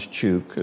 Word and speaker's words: Chuuk: The island is Chuuk, is that right. – Correct? – Right.